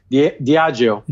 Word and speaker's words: Diageo: Yeah, did I get that last brand right? – Diageo. – Diageo, very good.